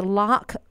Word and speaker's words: Larq: Now, the first one is the Lark Bottle Filtered. It is spelled L-A-R-Q.